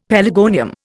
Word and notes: pelargonium: Indian

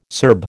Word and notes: Serb: American English

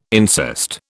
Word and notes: incest: British English

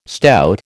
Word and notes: stout: American English

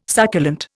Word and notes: succulent: Indian

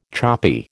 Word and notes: choppy: American English

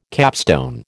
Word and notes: capstone: American English

